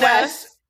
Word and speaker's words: Wesch: It's Wesch. – Wesch. So there's Wesch. No L in it.